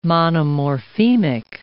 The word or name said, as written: monomorphemic